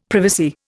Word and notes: privacy: Indian

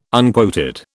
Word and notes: unquoted: British English

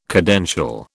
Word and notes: cadential: British English

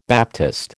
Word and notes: baptist: American English

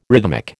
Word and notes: rhythmic: American English

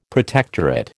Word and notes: protectorate: American English

